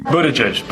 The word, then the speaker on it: Buttigieg: Buttigieg, but around South Bend, they just call me Mayor Pete.